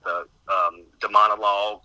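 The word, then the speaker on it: Daemonologie: It's pronounced something like the Demonologue, as it was called.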